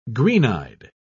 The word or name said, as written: green-eyed